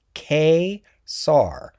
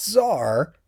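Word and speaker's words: Ka-Zar: and it says pronounced K-Sar, not Czar, but Sar, K-Sar, which is weird because, of course, it's K-Czar or Kazar or whatever.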